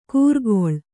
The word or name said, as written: ಕೂರ್ಗೊಳ್